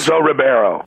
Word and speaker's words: Ribeiro: Carlton, Alfonso Ribeiro, I'm not sure exactly how you pronounce his last name. – Ribeiro.